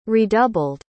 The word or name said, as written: redoubled